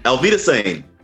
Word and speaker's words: Auf Wiedersehen: And Alvita Sane. Did I say that correctly? – Auf Wiedersehen. Yeah. Like a proper German person.